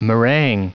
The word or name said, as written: meringue